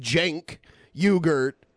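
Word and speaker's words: Cenk Uygur: The Young Turks founder, Cenk Uygurt, it's pronounced Cenk Uygurt, files to run for Katie Hill's House seat.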